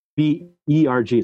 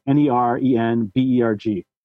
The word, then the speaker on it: Nerenberg: It's Albert that everybody knows how to spell, but Nerenberg is N-E-R-E-N. Nerenberg. It's all E's. Nerenberg.